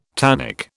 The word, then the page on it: tannic: British English